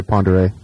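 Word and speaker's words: Pend Oreille: It looks like it's P-E-N-D, and that's pronounced pond. – Pond. – And then, so it's Pondere, even though it looks like Pandorielli.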